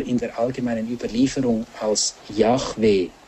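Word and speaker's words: Jahwe: Der Name Gottes ist ja nach dem Alten Testament, wie er ihn beim brennenden Dornbusch Moses offenbart hat, IHWH, Yahweh. Es gibt da eine falsche Übersetzung, die dann auf Jehova kommt. Und das ist eine monströse Aussprache, heißt es im Lexikon für Theologie und Kirche.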